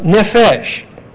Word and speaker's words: nephesh: These are little e's, short e's, hence pronounced nephesh.